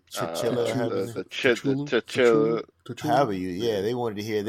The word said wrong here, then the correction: Cthulhu: I was like, I know it's spelled weird, but I've heard it pronounced Cthulhu.